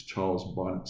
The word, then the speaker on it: Charles Bonnet: Charles Bonnet syndrome is the French pronunciation, because it was actually documented in 1760 by Charles Bonnet, in 1760. In English, it's Charles Bonnet syndrome, and the acronym is CBS.